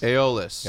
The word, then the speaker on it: Aeolus: Aeolus.